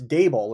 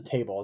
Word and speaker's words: Daboll: I'd be interested to ask him that because honestly, well, Joe Schoen, by the way, sorry, Joe, but you don't pronounce the head coach's last name correctly, number one. It's Daboll, Lorenzo Daboll. It's not Daboll.